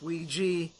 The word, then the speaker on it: Ouija: I'm sorry, I know that it's spelled O-O-U-J-A, and it's pronounced Ouija, but still, come on. Every time I look at that word, I think Ouya for some odd reason.